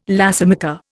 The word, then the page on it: lacemaker: Indian